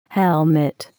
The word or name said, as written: helmet